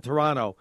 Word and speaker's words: Toronto: And by the way, there's no second T when they pronounce that. It's Toronto. I remember when I got banned in the United States, I worked up in Toronto.